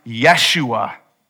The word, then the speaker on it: Yeshua: Joshua's name, actually, it would have been pronounced Yeshua, which means Yahweh is our salvation.